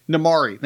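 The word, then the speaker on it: Namaari: Is it Namari? – Namari.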